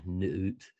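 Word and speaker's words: Nut: Is that I thought that was I don't even know how to pronounce it, but Nuut or Nuut. But that was the lady in the sky. – Yeah, and that has to do, yeah, Nuut. That sounds right. That's right.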